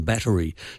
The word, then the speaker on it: battery: The Oxford definitely gives a three-syllable pronunciation, battery, so the little "a" sound in the middle, we are meant to say, that's the correct pronunciation. But when we're speaking quickly, we often drop it, and I noticed it when you were doing it, it was sometimes there and sometimes not there.